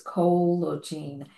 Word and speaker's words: Collagene: And it looks like it almost is like collagen, but it's not. It's Collagene. And what it's going to do is totally rebuild knees and hips.